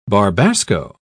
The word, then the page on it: barbasco: British English